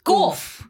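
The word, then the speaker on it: Gauff: How do you pronounce it? – Gorf. Is that Gorf? Gorf. Ollie said Gorf. So we're going to go with Gorf.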